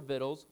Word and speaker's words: victuals: And they took all the goods of Sodom and Gomorrah and all their victuals, look it up, that's how you say it, and went their way.